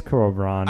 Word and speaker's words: Cuarón: Directing went to Alfonso Cuarón from Roma. – That's how you pronounce his last name? – Definitely not. – Oh, okay. – I say Cuarón. It's Cuarón.